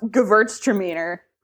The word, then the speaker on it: Gewürztraminer: is a German wine, and it's pronounced Gewürztraminer, and people are really impressed that I know how to pronounce that.